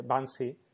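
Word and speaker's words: Banksy: Bansi.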